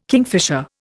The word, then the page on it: kingfisher: Indian